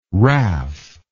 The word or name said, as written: WRAF